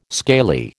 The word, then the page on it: scaly: American English